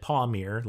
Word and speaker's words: Palmir: Okay, so they blow up the planet Palmyre. Is that what they say? – So yes, they pronounce it Palmyre, like P-A-W-M-I-R. It's, I believe it's spelled P-A-L-M-I-R, Palmyre.